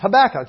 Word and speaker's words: Habakkuk: I mean, we're the kind of people who know that Habakkuk is Habakkuk. That's how you say it.